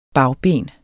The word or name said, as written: bagben